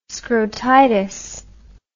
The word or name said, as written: scrotitis